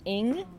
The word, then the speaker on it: Ng: Okay, now we have Little Fires Everywhere, and this is by Celeste, and I believe her last name is pronounced Ing.